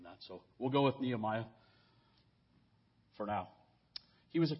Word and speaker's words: Nehemiah: As I understand it from those who study Hebrew, his name is more probably pronounced Nehemiah, because of how the Hebrew language is, but it's so inculcated in my mind as Nehemiah.